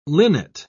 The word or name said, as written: linnet